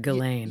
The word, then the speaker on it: Ghislaine: Unfortunately, so did his girlfriend, who I'm not sure if I'm going to say her name right, which is, I think, is Ghislaine Maxwell. – That's what I heard, Ghislaine.